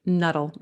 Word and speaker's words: Nuttall: Nuttall, like subtle. Nuttall.